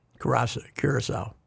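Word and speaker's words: Curaçao: Wilmenstead, Caraco, Netherlands. – Curacao. – Caraco.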